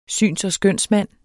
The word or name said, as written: skønsmand